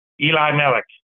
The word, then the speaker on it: Elimelech: Eli is God, and Melech is God King. Eli Melech. I pronounce it Elimelech.